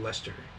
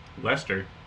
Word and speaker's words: Leicester: Red Lichester cheese. Actually, my mom said that we said it wrong every single time. It's actually red. I think she said red Leicester. – Leicester. – Red Leicester cheese.